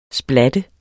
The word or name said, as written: splat